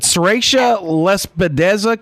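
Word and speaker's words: sericea lespedeza: Is it Serratia lespedeza? Control? Is that pretty close? – Yeah, pretty close.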